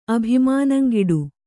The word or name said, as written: ಅಭಿಮಾನಂಗಿಡು